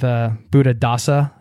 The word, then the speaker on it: Buddhadasa: Buddhadasa, am I saying that correctly? – That's it.